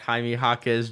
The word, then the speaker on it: Jaime Jaquez: I didn't say Jaquez. I said Jaquez. I know you don't pronounce bad. – You don't pronounce the U.